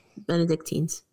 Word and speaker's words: Benedictines: Originally, the Basilians, until 1176, followed by the Benedictines. How do you say that again? – Benedictines.